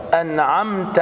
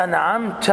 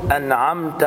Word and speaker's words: أنعمت: انعمت تاء عندك خلاص مفتوحة فتنطق هكذا أنعمت عليهم، بعضهم يقرأ هكذا أنعمت عليهم، نعمت حتى هذه لهجة عند بعض الناس، لكن أنا رحت واستعملتها، هذا ليس صحيح، هذا لا صح. فأقول أنعمت عليهم، مهم جدا هكذا خلاص.